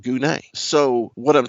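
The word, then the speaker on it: gune (γυνή): The word is gune. That's a weird word, but that's how you pronounce it.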